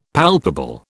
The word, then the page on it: palpable: British English